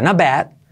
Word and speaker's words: Not bad: Fíjate cómo se pronuncia esto. Not. Esa T casi no se pronuncia. Not bad. Not bad. Y esa E, bad, como thanks.